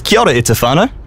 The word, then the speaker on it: kia ora e te whānau: Hey, I recently learned the right way to say hello family in te reo Māori. Is kia ora e te whānau? – Mean.